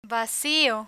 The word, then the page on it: vacio: Spanish